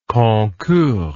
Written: concours

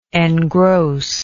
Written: engrosser

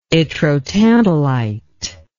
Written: yttrotantalite

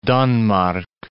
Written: Danmark

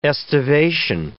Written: estivation

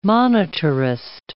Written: monetarist

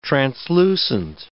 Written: translucent

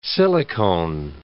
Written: silicone

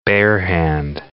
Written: barehand

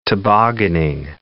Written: tobogganing

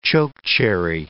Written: chokecherry

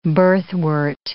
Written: birthwort

